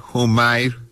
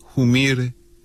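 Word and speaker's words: Humire: Joseph Humer Humair, pero como se pronuncia originalmente en Bolivia, Humir, es uno de los funcionarios más importantes del Pentágono, como se conoce al Departamento de Defensa de Estados Unidos.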